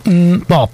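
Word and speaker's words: MMMBop: I wasn't 100% certain how to pronounce it, to be honest, but a short while ago, that song was featured on Gareth Herfoot's programme, and he pronounced it Mbop.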